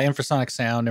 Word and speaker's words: Infrasonic: Of course, his studio here in Nashville is Infrasonic Sound. Am I saying it right? – Yeah, Infrasonic Sound, Infrasonic Mastering.